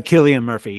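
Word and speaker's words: Cillian: But I really do think he'll win an Oscar for it, because his, And so I also think, how do you say his name? Is it Killian? – Yeah, Killian Murphy.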